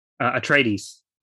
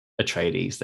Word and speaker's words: Atreides: Atreides. – Atreides, there we go.